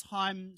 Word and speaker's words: Haim: How's Haim spelled? It's spelled differently. – H-A-I-M. – Yeah, right. – Haim. It's like they're Jewish.